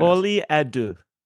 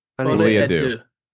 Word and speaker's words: Folie à deux: Folie à deux. – Folie à deux.